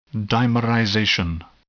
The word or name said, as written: dimerization